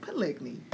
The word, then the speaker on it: polygyny: Polygyny. – Did I say it right? – Polygyny Lord, couple. – I said it right. Polygyny.